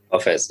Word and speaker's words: Hafez: And we were looking at some of the poetry, and there's one famous poet from, I hope I pronounce this correctly, but Hafez? – Hafez, yeah.